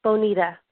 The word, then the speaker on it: Bonita: Hi, this is Bonita. How are you? – What was your first name? – It's Bonita. – Okay, could you spell it? – Mm-hmm. B like boy. O-N-I-T-A.